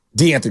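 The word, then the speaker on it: De'Anthony: So you had Curry, Dre, Wiggins, Kuminga, and Anthony Melton. – Yep. D'Anthony, excuse me. – I think I've mispronounced that five different times in the last 2 weeks.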